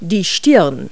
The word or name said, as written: forehead